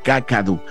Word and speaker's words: Kakadu: Se pronuncia Kakadu, sin el acento, Kakadu.